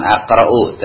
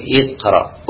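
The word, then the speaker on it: اقرأ: Iqra dibacanya bukan aqra'u tapi iqra, uktub.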